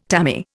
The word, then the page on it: tammy: Indian